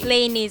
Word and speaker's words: Lainey: Is it Lani? – Lainey, sorry.